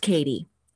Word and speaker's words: Katti: So those of you listening, my name is spelled K-A-T-T-I, but it's pronounced Katie.